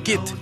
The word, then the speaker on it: git: Recordando a Sir Raleigh, introdujo a la corte de Elizabeth I el tabaco que habría traído de América, insultando a Raleigh llamándolo git, que significa imbécil, y que en Liverpool se pronuncia get, añadiendo en la canción para que rimara con cigarette.